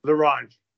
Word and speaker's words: La Ronde: Now, how do you pronounce La Ronde? Am I saying it right? – La Ronde. – La Ronde. – Yeah.